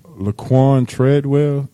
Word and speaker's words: Laquon Treadwell: Laquon Treadwell, did I say that right? – Mm-hmm. Yeah, you did.